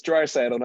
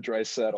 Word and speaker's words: Draisaitl: Yeah, the craziest part about that is that Leon Draisaitl, which it's Draisaitl, not Draisaitl. I'll give you points for at least trying to pronounce the German name.